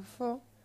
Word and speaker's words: faut: escrito IL y en otra palabra FAUT pronunciado fo, que significa hay que.